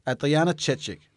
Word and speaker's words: Adriana Chechik: Altså, dobbelt porno-Oscar-vinder Adrian Chechik. Adriana Chechik, nu skal jeg udtale det ordentligt, var et oplagt sted til lige at få nogle fif.